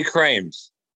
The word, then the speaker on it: Krames: Actually, it's Jeffrey Krames.